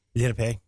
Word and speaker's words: Lenape: I think I'm pronouncing that correctly. The Lenape? – Lenape.